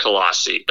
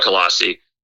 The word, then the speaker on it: Colossae: And so anyway, okay, so it's called Colossae. That's what it is. And so I'm not really good at pronouncing Greek and Roman words still, but anyway, it's called Colossae.